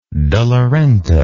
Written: de la renta

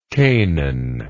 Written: chanaan

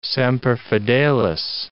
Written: semper fidelis

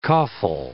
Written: coffle

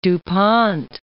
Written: Du Pont

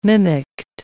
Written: mimicked

